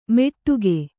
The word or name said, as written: ಮೆಟ್ಟುಗೆ